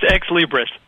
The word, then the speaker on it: Xlibris: And before joining Afilias, he was a vice president and chief marketing officer at, I can't even pronounce it, X-L-I-B-R-I-S. How do you pronounce that? – It's Ex-Libris.